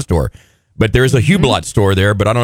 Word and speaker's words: Hublot: And help me with this, a Hublot watch? – Oh, I've seen that at the very fancy malls, but I have no idea how to pronounce it. It's Hublot.